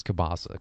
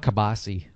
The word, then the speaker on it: kielbasa: I think this is the first time I've heard you pronounce kielbasa correctly. Otherwise, you're like kabasi.